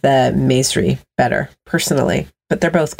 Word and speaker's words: Maesri: Maisri, M-A-E-S-R-I.